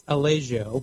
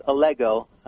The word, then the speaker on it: Allego: it's it's pronounced Allego, uh, rhymes with Lego my ego.